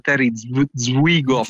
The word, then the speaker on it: Terry Zwigoff: vado a sbagliare la pronuncia, da Terry Zwigoff. – Credo si dica così. È giusto.